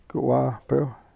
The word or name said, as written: ku w-ahpew